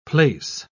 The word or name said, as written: place